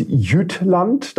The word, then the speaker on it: Jütland: Das Giant Mouse, ich sage jetzt Jütland, damit mich nicht wieder irgendjemand korrigiert. Ich glaube, so spricht man es richtig aus.